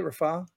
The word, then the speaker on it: Rafah: Is that how you say it, Rafah? – That's exactly how you said it.